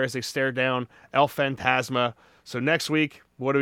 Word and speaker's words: Fantasma: Or Fantasma, did I say his name correctly? – That's right.